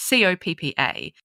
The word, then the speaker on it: COPPA: Is it COPPA or COPPA? It's COPPA.